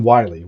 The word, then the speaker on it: Wylie: and then Wiley, which is spelled W Y L I E.